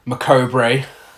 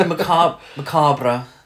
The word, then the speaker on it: Macabre: And or as the trailer calls it, Macobre. – No, Macabre. That's closer to the French pronunciation.